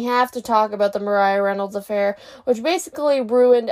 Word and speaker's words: Maria: All right, let's get into the sticky situation of their marriage the Maria Reynolds affair. Actually, I think it's pronounced Mariah. I think it's pronounced Mariah. Mariah Reynolds.